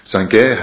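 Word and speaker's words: Sangye (སངས་རྒྱས): The Tibetan word is Sangye. Sangye has two syllables. The first is Sang and the second Ge.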